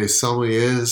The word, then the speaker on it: sommeliers: We smell poop the way sommeliers smell wine. Hey, did I say it right? – I think you said it beautifully.